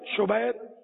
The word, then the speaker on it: شبیر: اعلیٰ حضرت نے اس کا تلفظ ہی بیان کیا, شبر, شبیر, مشبر.